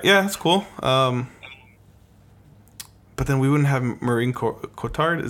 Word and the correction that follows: Marion Cotillard: Is that how you say her name? – Marion Cotillard.